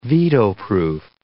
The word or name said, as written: veto-proof